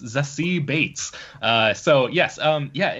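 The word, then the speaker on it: Zazie Beetz: So uh, first of all, I, I just learned recently that this actress's name is pronounced Zassie Bates, like, like as in oh, Bates really? Motel.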